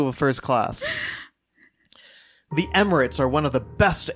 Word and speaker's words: Emirates: Is that how you say it? Oh, I never said it. – E-M-I-R-A-T-E-S. Yeah, Emirates.